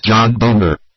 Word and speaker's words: Boehner: I don't think there's a D in Boehner. – John right. Boehner.